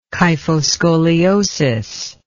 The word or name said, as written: kyphoscoliosis